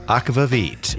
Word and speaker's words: aquavit: And we now know how to pronounce aquavit. – Aquavit. – Yes. So I've heard aquavit. I've heard aquavit. But now we know from an expert.